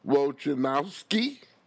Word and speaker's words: Wojnarowski: A first-round draft pick from the Milwaukee Bucks, according to ESPN Andre Wojnarowski.